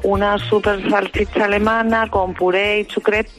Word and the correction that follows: chucrut: por chucrut, no sé cómo se dice. – Chucrut, sí.